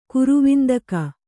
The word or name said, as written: ಕರವಂದಿಗೆ